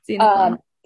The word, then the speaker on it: Xenophon: yeah, there was a reading on them about Plato said that he'd never earned money from his teachings, whereas I'm sorry, I don't know how to pronounce his name, Xenophon. – I believe, yeah, Xenophon.